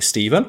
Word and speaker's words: Stephen: So apologies if you pronounce it Stefan. I'm going to pronounce it Stephen.